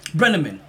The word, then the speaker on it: Brenneman: Tom Brennan. – Tom Brennan. – Brenneman. Let's make sure we get his name right.